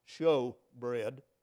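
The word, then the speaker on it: shewbread: Because these loaves of showbread, and it's going to be spelt S-H-E-W in some of your versions, but it's basically pronounced showbread, were set up as sacrifices to God.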